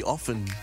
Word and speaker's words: often: There's no, the T is silent, so it's supposed to be often.